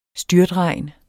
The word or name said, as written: styrtregn